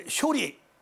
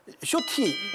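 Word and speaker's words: shorty: 이제 쇼리 이런 식으로 얘기하면 어르신들 중에는 네. 미안하대 이제 쇼리를 아, 네. 잘못 발음 하나 하시는데 이 키가 작다는 쇼티 네. – 예, 예. 아. 라고 합니다.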